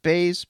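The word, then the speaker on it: Buys: But I'm interested to see Cheyenne Bays. I don't know why it's pronounced Bays, but it is.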